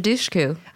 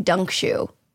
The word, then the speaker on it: Dushku: Elisha Dushku. – Dushku? – I think her last name's Dushku. – I've been pronouncing it wrong my entire life. I've been saying Dunkshu, which is probably not right.